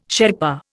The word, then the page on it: Sherpa: Indian